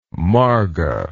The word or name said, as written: marga